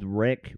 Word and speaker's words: REC: So this one's called Wreck, which is spelled R-E-C as in record.